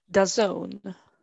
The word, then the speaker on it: DAZN: You can watch that on, how do you say their name? DAZN? DAZN? – DAZN.